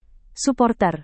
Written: suporter

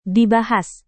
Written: dibahas

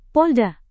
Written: polda

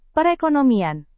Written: perekonomian

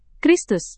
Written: kristus

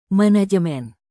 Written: manajemen

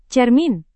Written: cermin

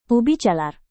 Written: ubi jalar

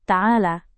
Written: taala

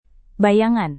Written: bayangan

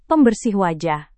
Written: pembersih wajah

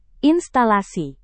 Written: instalasi

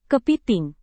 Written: kepiting

